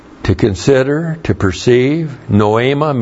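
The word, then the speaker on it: noema (νόημα): That's N-O, long E-M-A, noema.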